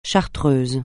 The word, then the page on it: chartreuse: français